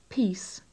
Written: peace

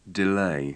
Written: delay

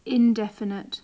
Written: indefinite

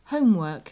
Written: homework

